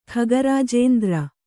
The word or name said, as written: ಖಗರಾಜೇಂದ್ರ